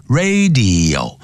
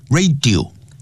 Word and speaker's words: radio: 就像收音机叫 radio 不要念成 radio radio serious serious radio、啊、这样念 radio 像影像呢视频叫 video video 不要 video、啊、 video radio serious Serious problems to humans, 对人类啦 plants, 植物啦 animals, 动物 and the entire environment as a whole, 以及包括整个环境。